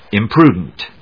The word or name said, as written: imprudent